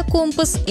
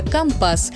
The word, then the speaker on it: компас: Так и получилось, что одно слово живет сразу в двух вариантах – сухопутное «компас» и морское «компас». И в этом есть своя профессиональная логика. На флоте язык всегда чуть более строгий, точный и свой. И если услышали такое ударение, это уже не ошибка. Это просто другой профессиональный язык.